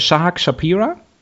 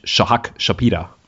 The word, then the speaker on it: Shahak Shapira: Ich habe ihn jetzt so englisch ausgesprochen, das ist totaler Quatsch. Ist ein, in Deutschland dem da äh, äh, Israeli, also heißt er wahrscheinlich Shahak Shapira.